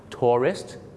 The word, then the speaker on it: tourist: tourist ส่วนผู้ก่อการร้าย terrorist 2 คำนี้มีเส้นบางๆอยู่นะครับในการออกเสียงคือพยางค์2 พยางค์ tourist นักท่องเที่ยวกับ terrorist 3 พยางค์ครับผมน่ะกลัวนะครับว่าคุณจะเป็นเหมือนลูกศิษย์ผมคนนึงครับฝรั่งถามว่า Why did you come to America?